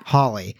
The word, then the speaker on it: Holly: growing up in Southern California and having lived in Utah for 12 or more than that years, I say it Holly. You like, say it like, Holly. – So you say it like this a space in a house, a hall-y. – Yeah. – Holly. Uh-huh. But I say Holly, like ha ha ha.